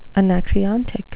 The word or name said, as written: anacreontic